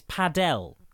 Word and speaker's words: padel: And it's not paddle tennis. It is padel tennis. P-A-D-E-L, right? That's P-A-D-E-L. – How you say it. – P-A-D-E-L, okay.